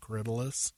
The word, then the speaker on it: Corydalis: It's spelled Corydalis, but it's pronounced Corydalis.